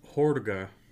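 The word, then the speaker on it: Hårga: Most of the Swedish dialogue spoken by the Hordiga. Yeah, I said that right. I looked it up so I wouldn't fuck it up.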